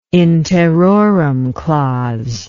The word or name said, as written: in terrorem clause